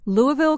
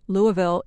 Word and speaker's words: Louisville: If you want to sound like a true Southerner, pronounce this city in Kentucky as Louisville, not Louisville, and definitely not Louisville. If you do, they will think you're a Yankee from the North.